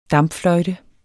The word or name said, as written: dampfløjte